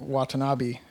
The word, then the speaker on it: Watanabe: So the other half of this point is the not enough Ken Watanabe.